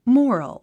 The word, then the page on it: moral: mɔ’ːrəl; モォロォ